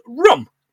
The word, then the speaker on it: rheum: Uh, the crust in your eye, John, when you wake up, is called rum, R H E U M, but pronounced rum."